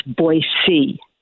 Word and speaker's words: Boise: It's Boise. B-O-Y-S-E-E is how you pronounce it.